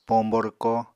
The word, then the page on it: pombo rwko: Apatani